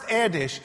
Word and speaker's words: Erdos: It's pronounced Erdos, E-R-D-O-S.